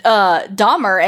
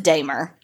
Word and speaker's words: Dahmer: Oh, well, let's just take it back to the first episode, whenever I said it was Dahmer and his name is pronounced Dahmer.